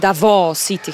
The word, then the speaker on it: Davao City: która jest częścią Davao City, chyba tak to się powinno wymawiać.